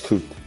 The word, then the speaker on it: Kute: Yes, Kute.